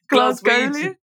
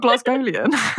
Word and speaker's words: Glaswegian: It's like, I really, really, really wanted to go because all of Dad's family is how do you say Glaswegian? Not Glasgowian.